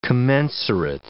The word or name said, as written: commensurate